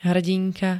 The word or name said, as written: hrdinka